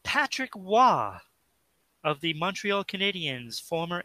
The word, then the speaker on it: Roy: Patrick Roy for us Americans that don't want to pronounce it Waugh.